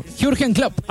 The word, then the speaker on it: Jürgen Klopp: Jürgen Klopp. – Jürgen Klopp. – Dígalo de nuevo. – Jürgen Klopp.